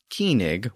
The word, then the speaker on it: cyning: So again, that word C-Y-N-I-N-G, the original version of the word king, was still being pronounced keenig with the original K sound.